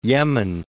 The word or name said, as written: Yemen